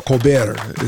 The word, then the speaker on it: Colbert: So Colbert is how you pronounce his name.